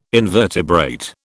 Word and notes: invertebrate: British English

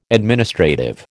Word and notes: administrative: American English

